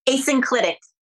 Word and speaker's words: asynclitic: In the end, she was asynclitic. So she was off kilter. Did I say it wrong? How does it? – Asynclitic. – Asynclitic. Thank you.